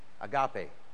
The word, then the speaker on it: Agape: Agape. Do you know how to spell that? A-G-A-P-E. Agape.